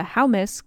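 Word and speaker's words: Houmas: So what we are going to pronounce is the Haumus, because it's H-O-U, much like house.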